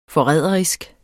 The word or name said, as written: forræderisk